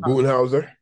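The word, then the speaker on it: Budenholzer: What's his name? Bullhouse. I can never say his name. Bullhouse. – Boonhauser.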